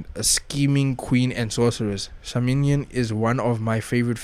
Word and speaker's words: Samhain: These celebrations focus on the ancient feast of Samhain, pronounced Samhain, and sometimes spelled Samhain, from which many of today's Halloween traditions originate.